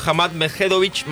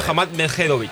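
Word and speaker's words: Hamad Međedović: Va a estar jugando con el serbio Hamad Medvedović. – Qué bien, qué bien que pronuncia. – Y lo repite perfecto las dos veces. Yo quería ver si pisaba, pero no.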